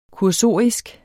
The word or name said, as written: kursorisk